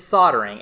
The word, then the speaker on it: soldering: Is it pronounced soldering or soldering? – It's soldering. It's just there's an L in the word, and I always forget that.